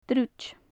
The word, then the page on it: druid: gaelic